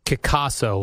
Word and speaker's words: Kickasso: Kickasso, like it's pronounced like Picasso.